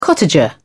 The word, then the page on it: cottager: British English